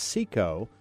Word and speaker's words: SEKO: it's pronounced SECO, SECO Logistics, but it's spelled S-E-K-O.